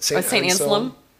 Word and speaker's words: Anselm: He is going to St. I might be pronouncing it wrong, but St. Anselm.